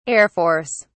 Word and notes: Air Force: British English

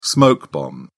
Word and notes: smoke bomb: British English

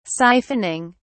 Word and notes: siphoning: British English

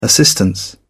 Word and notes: assistance: British English

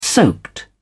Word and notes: soaked: British English

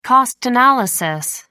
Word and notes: cost analysis: American English

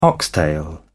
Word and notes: oxtail: British English

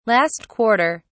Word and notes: last quarter: British English

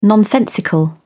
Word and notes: nonsensical: British English

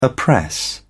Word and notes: oppress: British English

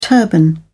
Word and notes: turban: British English

